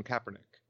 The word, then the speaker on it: Kaepernick: Both, I feel, have some justification for their argument, which is Colin Kaepernick.